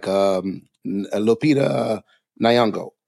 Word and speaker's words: Lupita Nyong'o: And then we have a case like Lupita Nyong'o.